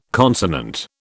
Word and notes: consonant: British English

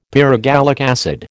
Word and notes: pyrogallic acid: American English

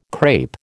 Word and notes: crape: American English

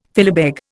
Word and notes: philabeg: Indian

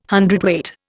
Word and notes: hundredweight: Indian

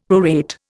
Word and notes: prorate: Indian

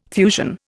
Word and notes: fusion: Indian

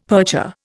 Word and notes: percher: Indian

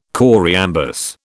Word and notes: choriambus: British English

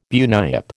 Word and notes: bunyip: American English

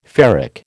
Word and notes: ferric: American English

